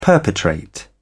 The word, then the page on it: perpetrate: British English